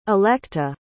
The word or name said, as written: electa